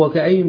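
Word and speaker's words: وكأين: بالنون، فدي لما نقف عليها ننطقها نون ولا ننطقهاش نون؟ نقول ايه؟ وكأي ننطقها نونا، واضح اخوانا؟ مش هنقول وكأي، هو الطبيعي القاعدة انها ما تنطقش التنوين وتصير ايه؟ سكونا، لا هنا نطقناه قلنا وكأي لان ده رسم المصحف، خلاص؟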